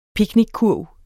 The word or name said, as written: picnickurv